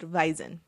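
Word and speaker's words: Wiesn: It's pronounced Weizen.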